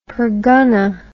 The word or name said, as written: pergunnah